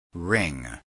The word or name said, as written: ring